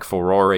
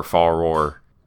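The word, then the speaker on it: Farore: As an aside, it just occurred to me that, like, Furore is the only pronunciation that makes sense with, like, the way that it would be read in Japanese, so I guess I've been calling her Faror erroneously for 23 years now, but I'm walking it back.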